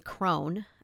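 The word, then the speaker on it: Cron: It's an interview with Ian Crone, I believe is how you pronounce his last name. It's C-R-O-N.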